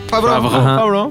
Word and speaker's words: Favreau: Yo quiero recomendarles una película que se llama Chef el ah, protagonista es John Favreau. Ah, ¿en ¿Lo serio? ¿Lo dije bien? Favreau, Favreau, Favreau, No, sí, muy bien.